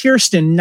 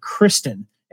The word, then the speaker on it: Kirsten: Chris, Kirsten, by the way, it's pronounced Kirsten, not Kristen.